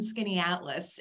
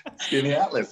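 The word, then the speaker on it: Skaneateles: I like that you just pronounced Skinny Atlas for me because I pass that sign all the time and I have no idea how to pronounce it. So Skinny Atlas, that's great.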